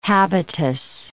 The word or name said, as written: habitus